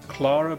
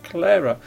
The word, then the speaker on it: Clara: Clara is C-L-A-R-A. Many people will say Clara, but it is pronounced Clara. It rhymes with Sarah.